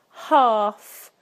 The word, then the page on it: half: British English